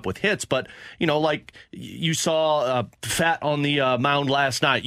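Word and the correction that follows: Pfaadt: Is it not pronounced fat? – Fought.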